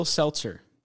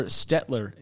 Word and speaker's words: Stelter: Will Stelter. That's right. Or Stetler, if you're 80% of the people who pronounce my last name.